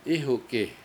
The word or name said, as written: Ihuk7i